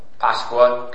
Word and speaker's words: Pasqua: Pascua. – P-A-S-Q-U-A.